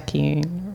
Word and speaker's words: tektite: It's a tektite.